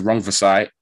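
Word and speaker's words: Rungvisai: It's a remember the name kind of moment, because what he's done to Rung Safai in 8 rounds, I'm probably butchering that name, innit? How do you say it? – Rung Vasai. – What he did to Rung Vasai in 8 rounds, special stuff.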